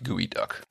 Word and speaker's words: geoduck: So it's spelled geoduck, but it's pronounced gooey duck.